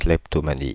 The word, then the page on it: kleptomanie: France (Paris); /klɛp.tɔ.ma.ni/